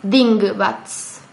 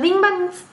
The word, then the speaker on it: dingbats: Y los dingbats, dingbats, me cuesta un montón pronunciarlo, así que perdónenme. – Es una linda palabra igual. Es ding como ding y bats como murciélago en inglés.